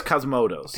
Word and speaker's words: Cosmatos: I was not the biggest fan of Cosmotos. How do you say his last name? – Panos Cosmatos.